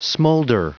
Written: smolder

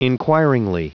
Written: inquiringly

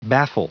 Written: baffle

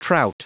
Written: trout

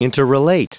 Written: interrelate

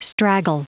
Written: straggle

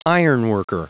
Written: ironworker

